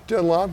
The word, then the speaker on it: Dunlop: Dunlop? – Yeah, Dunlop. I think that's right. – So Dunlap.